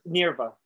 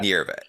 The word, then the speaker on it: Nierva: What was, and I would love to get in a podcast with, I was telling my brother, with Ricky Nivera. Is that how you pronounce his last name? – Yeah, Ricky Nierva. Yeah. – Nierva.